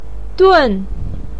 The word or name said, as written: dun4